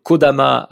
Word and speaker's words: Kodama: Et la, la premier changement qu'il y a eu, donc ça a été euh, Kodama avec le kanji de ki qui veut dire arbre, mais qui se prononce ko dans ce cas-là, et tama qui se prononce dama parce qu'il est associé, qui veut dire âme.